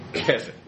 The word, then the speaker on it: Keswick: Keswick. By the way, that W is silent there. It's one of those British letter drops that we're familiar with.